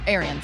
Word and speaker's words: Arians: How do you say his name? – Arians. – Bruce Arians. Arians.